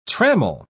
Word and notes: trammel: British English

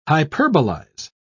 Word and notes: hyperbolize: British English

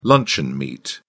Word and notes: luncheon meat: British English

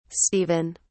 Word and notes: stephen: British English

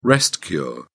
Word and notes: rest-cure: British English

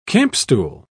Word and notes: campstool: British English

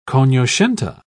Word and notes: cognoscente: British English

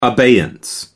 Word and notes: abeyance: American English

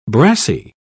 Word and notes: brassie: British English

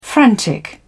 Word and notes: frantic: British English

